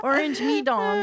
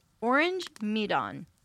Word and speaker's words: Orang Medan: When I was practicing this, I kept thinking it sounded like orange, me ding dong, like orange me dong or something like that. Anyway, that's how you pronounce it.